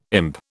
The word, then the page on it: imp: British English